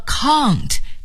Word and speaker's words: can't: C-A-N-T 撇 t count。在这里想请朋友们注意，如果您说 count，这是英音的发音方式，而说 can't 则是美音的发音方式。